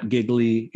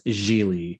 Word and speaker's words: Gigli: And then last but not least, it is probably the most mispronounced movie in all of cinema history. It is not Gigli. It is not Gigli. It is Gigli.